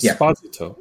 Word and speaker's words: Esposito: And season Esposito is how you pronounce his name. he was very clear in his interviews about that.